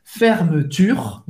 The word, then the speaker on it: Fermeture: Fermeture, Vous vai pronunciar o R aqui.